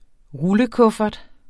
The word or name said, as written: rullekuffert